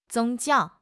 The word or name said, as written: 宗教